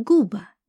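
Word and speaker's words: Quba: На азербайджанском этот город назывался с более звонким «к» и скорее произносился как «губа», но в русском языке название города традиционно начиналось с буквы «к». Куба стала известна уже после распада Советского Союза, еще и благодаря Красной Слободе, в которой компактно проживали горские евреи.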